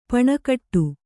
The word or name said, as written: ಪೆಣಗಾಟ